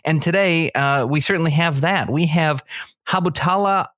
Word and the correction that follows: Hibatullah: Akunzada, who is, I hope I'm pronouncing that right. – No. Hibatula. – Hibatula.